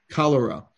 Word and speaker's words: cholera: One other thing is, do you know when the last world epidemic during, I'm going to mispronounce this, chloria, C-H-L-O-R-E-A. – Cholera.